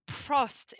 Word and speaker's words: Prost: Oh, prost. It's prost because that's prost. How you say it in Dutch.